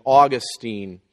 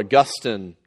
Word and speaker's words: Augustine: So he, for instance, starts off with Pelagius and Augustine, or Augustine, depending on your pronunciation of that, Luther and Erasmus and Calvin and Arminius and Edwards and Finney and some others as well.